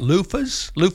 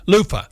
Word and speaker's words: loofah: You know, I had several people mention a month or so ago, we talked about luftas. – Right, sure. – How do you say it? – Loofah.